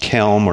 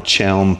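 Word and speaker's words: Chełm: I interviewed a pastor of a small Baptist church in, I think it's pronounced Kelm or Chelm, Poland, 30 minutes from the Ukrainian border.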